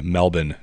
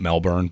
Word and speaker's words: Melbourne: I have a cousin that lives in Melbourne, and yes, that's how you say it, Australia. Melbourne, not Melbourne, but Melbourne. I say it that way because he lives there.